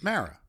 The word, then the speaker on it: Mara: Well, I'll say, and hearing you say my name, for some reason, people out here keep calling me Mara. – But it's Mara. – It's Mara. – And I did say it right, didn't I? – And you say it like a New Yorker, and you say it how I prefer.